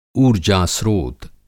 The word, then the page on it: ऊर्जास्त्रोत: Hindi